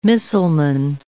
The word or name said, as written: missileman